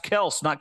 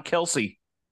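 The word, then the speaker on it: Kelce: Yeah, see, now I think you're right, because it says, how does Kelsey pronounce his name? The first syllable of his last name rhymes with out instead of being pronounced as lot.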